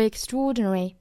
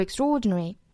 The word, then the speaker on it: extraordinary: Too extraordinary, too extraordinary, extraordinary 这个单词我们在周一的早读里面已经讲过了，也就是这个 tr 要浊化成 tr，并且这个单词的重读是在 o 这个位置。